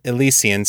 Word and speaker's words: Elysian: Head over to Kickstarter and support Elysian Springs, Adventures from the Nursing Home for Aging Superheroes. We changed the Joker's bedpan, so you don't have to. – And yes, if you're wondering, apparently it is Elysian Springs.